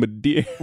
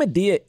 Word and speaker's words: Madea: Well, I think Madea is. Hold on, let me Google it before I say this wrong and someone sends me an email. – But they were saying Madir with an R.